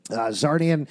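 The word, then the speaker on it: Czarnian: He is a Zarnian. I never know how to pronounce that. – That's right. – Okay. Zarnian.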